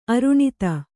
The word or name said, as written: ಅರುಣತೆ